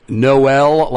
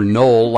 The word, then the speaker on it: Noel: And by the way, I forgot to ask you, is it Noel like the Christmas song or Noel like Noel Coward? – It's the latter, just Noel.